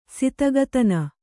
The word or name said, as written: ಸಿತಗತನ